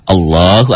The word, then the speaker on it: Allah: Allah, Allahu Akbar, gitu. A dulu, W, L, O, H. Itu bunyinya begitu. Kalau tulisan latinnya kan A, L, L, A, H. Allah. Dibacanya jadi Allah, Allahu Akbar.